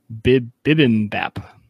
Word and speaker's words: bibimbap: I don't even know how to say this next word. B-I-B-I-M-B-A-P. Bibimbap.